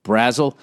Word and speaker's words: Brazel: It's Z-A-L, but it's Zul. Brazel.